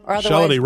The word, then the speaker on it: Shalady: Shalady rhymes with Melody.